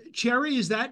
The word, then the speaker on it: Cherrie: Cherry, am I saying your name right? – Yes, perfectly. Cherry, yes. That's wonderful. – Right. – Like the fruit. – Yes, like the fruit, yes.